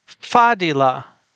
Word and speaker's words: Fadila: Well, I'm not putting a glottal stop there due to my own limitations, but the emphasis is on the first syllable. So, Fadila,